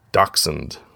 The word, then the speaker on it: dachshund: He had a dachshund, is how you pronounce that.